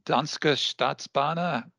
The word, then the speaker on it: Danske Statsbaner: It's Danske Statsbaner. How do we say that? That's correct.